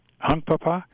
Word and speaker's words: Hunkpapa: Hunkpapa? – Yeah. – H-U-N-K-A-P-A. Hunkpapa. And then P-A-P-A. – Hunk. – H-U-N-K. Hunkpapa.